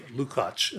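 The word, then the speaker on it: Lukács: And also, Gheorghe Lukacs, Lucacs. I think David Gordon told me it's called Lukacs, as he pronounced it.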